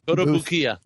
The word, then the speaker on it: Kotobukiya: The Kotobukiya. – Kotobukiya.